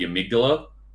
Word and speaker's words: amygdala: there is a creature in the game called the amygdala, or you could also pronounce it as the amygdala.